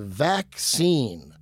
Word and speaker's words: Vacscene: Welcome to Vaccine. It's spelled V-A-C-S-C-E-N-E. So like vac, like, and then like scene, S-C-E-N-E.